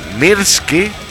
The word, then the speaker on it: Myrsky: Pero bueno, Mokoma, que además en este tema, este álbum se llama Mirski. Se escribe casi como el whisky, porque es... M-Y-R-S-K-Y. – Sí, parece whisky pero con M, Mirski.